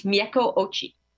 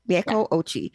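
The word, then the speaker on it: Mieko Ouchi: Now, I wanted to make it to your last production, The Red Priest, by Mieko Uchi. Did I say that right? – I believe it's Mieko Ochi. – Mieko Ochi. Yes.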